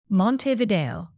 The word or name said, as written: montevideo